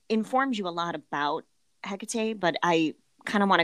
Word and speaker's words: Hecate: Well, as we just heard that little sound clip there, the little audio clip, it is pronounced Hecate.